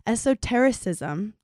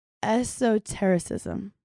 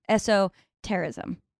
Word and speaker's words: esotericism: But she spends her days studying space and mythology, researching the history of esotericism. Did I pronounce that right? – No, but you said it beautifully. – Wait, let me try it again. Esotericism. – Softer C. – Esotericism. – Esotericism.